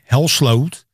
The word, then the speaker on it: Helsloot: It's always Misha Helsloot. It becomes everything. And I've always been looking for how to write down my name so in English they understand. So they pronounce it as Dutch.